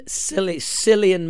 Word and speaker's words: Cillian: Directed by Christopher Nolan, starring Emily Blunt, Matt Damon, Cillian Murphy, for that is how he pronounces it.